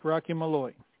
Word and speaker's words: Rocky Malloy: Rocky Malloy.